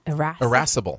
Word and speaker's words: irascible: Erasable? – Irascible. – Irascible, mm-hmm.